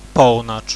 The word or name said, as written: pownacz